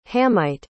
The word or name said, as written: hamite